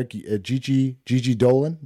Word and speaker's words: Gigi Dolan: Gigi Dolan, that's how you pronounce that, right? – Yeah.